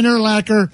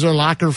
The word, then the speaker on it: Urlacher: Brian Urlacher, the linebacker. Remember, he was Urlacher for years, and he's Urlacher. – Well, yeah, so many times even commercials say his name wrong.